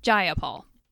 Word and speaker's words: Jayapal: If you're going to say my name, please say it right. It's Jayapal.